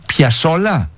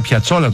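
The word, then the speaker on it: Piazzolla: Και Άστορ Πιασόλα. – Σωστότατα. – Μισό λεπτό. Το άκουσα να το λέει. Εγώ τον έλεγα Πιατσόλα τόσο καιρό.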